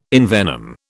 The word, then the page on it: envenom: British English